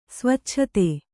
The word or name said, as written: ಸ್ವಚ್ಛತೆ